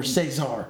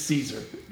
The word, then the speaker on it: Caesar: Is it Caesar? – Daniel Caesar. – That's what I've heard. – Or Cesar. – Yeah, I've heard people pronounce it that way too. – It's Caesar. – That those people that say Cesar probably say scenario.